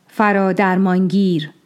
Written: Faradarmāngir

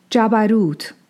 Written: Jabarūt

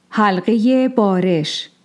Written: Bāreš

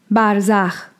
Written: Barzax

